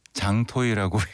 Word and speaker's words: JTL: 장종석 님이 선곡해 준 JTL A Better Day 장토이라고 읽어요.